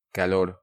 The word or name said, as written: Calor